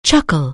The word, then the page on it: chuckle: anglais